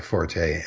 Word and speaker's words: forte: And the problem is really that most people are saying it just fine. They're saying, not my forte, and that is the English pronunciation.